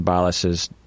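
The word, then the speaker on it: Balázs: I have no idea if I'm pronouncing Patron Balas's name correctly, but Balas. How do you spell it? – B-A-L, so Bal.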